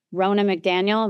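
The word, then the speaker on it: Ronna McDaniel: I wanted to talk to you about what has been going on this past week about Rona McDaniel. Am I pronouncing her name right? – Yeah.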